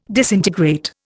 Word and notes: disintegrate: Indian

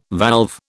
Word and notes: valve: British English

